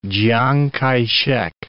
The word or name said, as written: Chiang Kai-shek